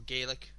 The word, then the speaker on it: Gaelic: The kind of like singing arts and music kind of like, you know, competition, Gaelic competition, or Gaelic, as you might want to pronounce it.